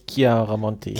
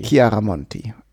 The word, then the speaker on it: Chiaramonti: Das ist mit CH? – Ja, mit CH. – Dann glaube ich Chiaramonti. – Chiaramonti. Ja, stimmt. – Wie der Chianti.